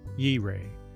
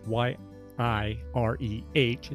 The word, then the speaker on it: Yireh: Yahweh Yireh. That's Y I R E H. And yes, I listened to the Hebrew pronunciation, so I think it's close.